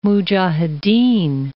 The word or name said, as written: mujahedin